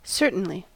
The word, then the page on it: certainly: American English